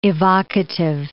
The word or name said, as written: evocative